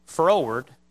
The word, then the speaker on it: froward: Plainly put, if you're going toward something, look at the way it's spelled, T-O-W-A-R-D, toward. You're going toward something. If you're going froward, you're going fro, to and fro. You're going from something, froward, toward, froward.